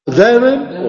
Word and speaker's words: therein: Therein o therein? ¿Cuál? ¿Marcando la primera o marcando la segunda? ¿Therein o therein? – Therein, mejor. Sí. Bien. Therein se pronuncia therein.